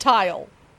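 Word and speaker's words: Tyle: Well, it's T-Y-L-E. – Yeah, but still Tile. It's pronounced Tile.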